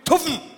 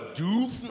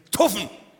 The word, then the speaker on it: tuffen: Heißt es jetzt tuffen oder dufen oder dofen? Nein, es heißt eindeutig tuffen. Ganz eindeutig. Deutsche Lautsprache. Tuffen heißt das.